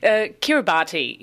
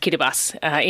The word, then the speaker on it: Kiribati: Kiribati is probably how you'd read it if you saw it, but it's pronounced Kiribati in Micronesia.